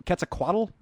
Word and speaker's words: Quetzalcoatl: I remember more about what Quetzalcoatl, is that how you say his name? – Yep.